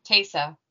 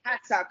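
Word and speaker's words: Taissa: Taysa Formiga. – Taysa. Yeah. I always want to say Tessa because it looks like Tessa, and I know that's wrong. – Because it's T-A-I-S-S-A, so I hope we're saying it right.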